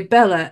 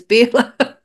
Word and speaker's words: Bela: But because of the way that we look at it, we say Bella, and I'm getting so used to saying Bella when in actual fact it's Bela.